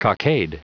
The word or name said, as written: cockade